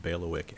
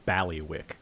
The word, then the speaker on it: bailiwick: which is the mispronunciation of bailiwick as ballywick.